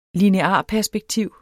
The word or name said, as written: linearperspektiv